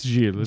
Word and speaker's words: Gilles: It's Gilles. It's Gilles a soft G.